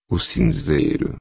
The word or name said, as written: cinzeiro